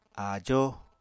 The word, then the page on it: áajó: apatani